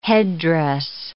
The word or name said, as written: headdress